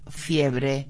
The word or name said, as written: fiebre